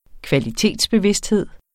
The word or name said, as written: kvalitetsbevidsthed